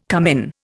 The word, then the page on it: cumin: Indian